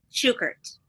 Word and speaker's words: Shukert: Shukert.